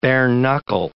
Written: bare-knuckle

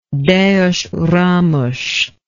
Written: Deus Ramos

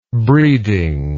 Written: breeding

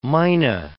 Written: myna

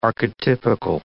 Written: archetypical

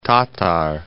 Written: Tatar Strait

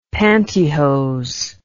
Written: pantyhose

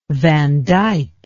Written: van dyck

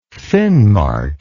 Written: finnmark

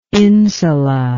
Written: insula

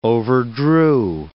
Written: overdrew

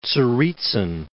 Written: Tsaritsyn

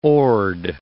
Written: oared

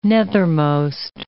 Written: nethermost

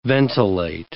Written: ventilate

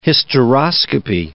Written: hysteroscopy

